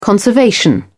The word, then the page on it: conservation: British English